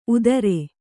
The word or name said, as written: ಊದರೆ